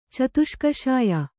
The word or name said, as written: ಚತುಷ್ಕಷಾಯ